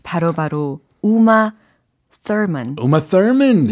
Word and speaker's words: Thurman: 근데 이게 한국 사람들은 우마 서먼. 아, 서먼? 네. 스스스스? 서먼. 아, 이거 좀 아니다. 그래요? 이거 좀 다른데요? – 그래서 우리는 이제 우마 thurman 이렇게 연습을 해 둬야 되겠죠? – Ah, uh, but there's no th pronunciation in Korean.